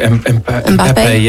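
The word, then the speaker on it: Mbappé: Mbappe.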